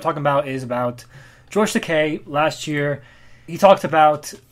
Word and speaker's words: Takei: No, it's Takei. He says it's okay to be Takei. – oh, okay. Like, as, as a right. joke, so. Right. Uh, come on, learn your Japanese pronunciation.